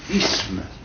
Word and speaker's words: isme: Mais la prononciation correcte, c'est isme.